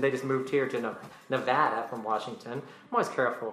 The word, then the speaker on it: Nevada: It's Nevada.